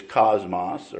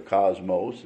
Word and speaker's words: cosmos: It's cosmos or cosmos. It's pronounced both ways.